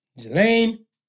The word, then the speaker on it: Ghislaine: Ghislaine Maxwell. Not the just Slane, but all the other people, not including you, would just look at it stuff and like, alright, fuck it.